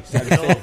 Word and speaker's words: Salcedo: Ya cuando digo el nombre, la primera vez lo pronuncio con Z, el nombre real es Sammy Salcedo.